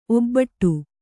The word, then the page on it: ಒಬ್ಬೊಟ್ಟು: Kannada